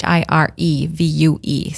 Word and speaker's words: HireVue: If you're looking into it, it's HireVue. So it's spelt a little bit differently.